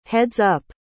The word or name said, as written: heads-up